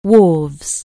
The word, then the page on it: wharf: anglais